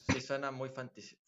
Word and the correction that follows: fantasiosa: fantasiosa. ¿Cómo se dice? Fantasiosa.